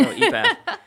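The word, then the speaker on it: ephah: By the way, when she goes back with an epath, did I say that right, Marissa? – Sure. – Okay, there we go, epath.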